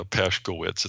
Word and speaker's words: Paskowitz: It's Paskowitz. It's only three syllables. It might look bad, but it's really not.